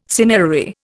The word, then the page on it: cinerary: Indian